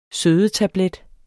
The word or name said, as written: sødetablet